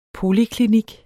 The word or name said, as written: POLIKLINIKKEN